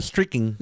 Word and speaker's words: streaking: Dice, ante cada striking ¿Cómo se lee esto? Ah, streaking. Streaking, así se le dice.